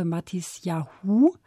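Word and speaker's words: Matisyahu: Mitgebracht haben Sie uns zur Einstimmung das Stück Jerusalem von der Gruppe Matis Yahu.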